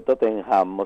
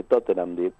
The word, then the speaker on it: Tottenham: Así lo decía yo cuando yo llegué, pero yo decía Tottenham, o sea, pronunciaba la H, Tottenham, digamos. – Claro. – Y no se pronuncia, cuando la H está en el medio se dice Tottenham directamente.